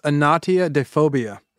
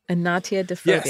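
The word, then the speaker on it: anatidaephobia: It's pronounced Anatia de phobia. – Anatia de phobia.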